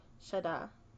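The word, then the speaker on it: Sha'da: Okay, Shada.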